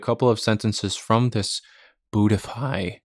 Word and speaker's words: Buddhify: So with that in mind, I'm going to pronounce this app name again. Buddhify. Buddhify. This is spelled Buddhify.